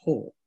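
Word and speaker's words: Haack: It says pronounced Hawk. – Yes, it is Matt pronounced Hawk,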